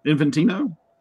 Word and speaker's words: Infantino: How do you say Carmine's last name there? – Infantino? – Thank you. That was my guess, right.